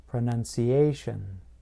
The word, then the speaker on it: pronunciation: Now, listen to this. Pronunciation. I place stress closer to the end of the word, just before the T-I-O-N ending.